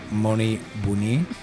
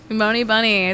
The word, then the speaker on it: Moni Bouni: We have Moni Bouni. Did I say it right? – You did it right. – All right. Or you can say Bunny. – Moni Bunny.